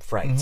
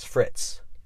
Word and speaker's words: frites: And on their menu, it said something in frites. Mm-hmm, mm-hmm. And I didn't know that, I didn't know you pronounce it frites. I just read it as Frittes.